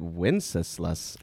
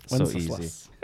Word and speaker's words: Wenceslas: I'm still impressed as you said Wenceslas. Wenceslas.